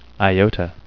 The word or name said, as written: iota